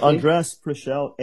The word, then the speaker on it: Andres Preschel: Yes, Andres PRESCHEL, ANDRESPRESCHEL.